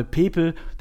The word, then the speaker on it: Pepe: Und der Name Pepe, der Spieler, um den es ging, den habe ich auch falsch ausgesprochen. Der wird nämlich Pep ausgesprochen.